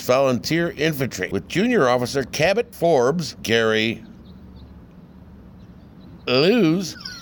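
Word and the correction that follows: Elwes: How do you say that? – Gary Elways. – Gary Elways. God, what is that, Scottish?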